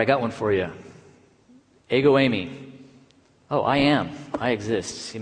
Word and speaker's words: ego eimi: Oh, it's ego eimi.